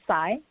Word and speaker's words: Tsai: Tsai.